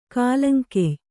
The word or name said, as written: ಕಾಲಂಕೆಯ್